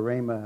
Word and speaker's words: Ramah: He lived in Ramah, which is Ramah, how you pronounce it, but he traveled every year.